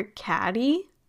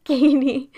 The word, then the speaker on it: Cady: Because Janis always called her Caddy. Mm-hmm. Why does she refuse to say her name right? like it's not that hard. It's just Cady.